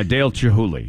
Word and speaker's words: Chihuly: a permanent display of works by the artist Dale Chihuly. – Yeah, Dale Chihuly. Yeah. – Okay, I am saying it right. – You're saying it right.